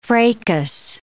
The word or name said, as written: fracas